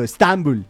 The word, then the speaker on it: Estambul: Estambul. Nosotros le ponemos el acento en otro lado. Estambul. Así le decimos nosotros, los más cercanos a esa tierra.